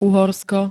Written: Uhorsko

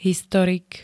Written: historik

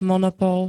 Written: monopol

